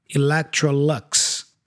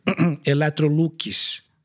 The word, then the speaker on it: Electrolux: I think the main difference here is the schwa sound. Yeah, because in English, we say lux at the end, right? The U has a schwa sound. Electrolux, right? In Portuguese, we pronounce the U as a U, yeah? So, Electrolux, Lucas, right? – Electrolux. – There you go. That's it. Electrolux. And I think we don't pronounce the C either. We say electro, not electro.